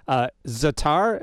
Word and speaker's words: za'atar: Za'atar, am I saying that right? – Yeah, that's right.